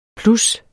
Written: plus

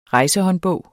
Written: rejsehåndbog

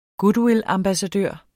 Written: goodwillambassadør

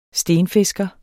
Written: stenfisker

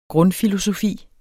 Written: grundfilosofi

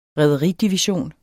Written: rederidivision

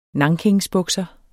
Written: NANKINSBUKSER

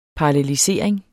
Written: PARALLELISERING

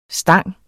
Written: stang

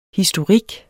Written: historik

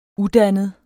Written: udannet

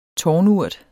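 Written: tårnurt

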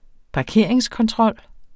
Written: parkeringskontrol

